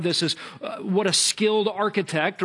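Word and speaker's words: architect (ἀρχιτέκτων): And the Greek word for master builder there is the word architect. It's pronounced architect.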